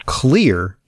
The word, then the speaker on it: Xlear: He's CEO and founder of Clear Inc. That's spelled X-L-E-A-R.